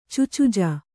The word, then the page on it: ಚುಚುಜ: Kannada